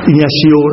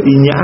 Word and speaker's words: Ignacio: Ignacio đó, ta cứ gọi là Inya hay là Ignacio cũng vậy, anh chị em nghe thì cũng là một vị thánh thôi. Vì phát âm mà phiên âm tiếng Việt đọc là thánh Inya. Còn nếu mà đọc nguyên tiên tên của ngài gọi là Ignacio, thì Ignacio đã tìm thấy Thiên Chúa bằng cách tìm hiểu và thương mến bước theo chân Đức Giêsu.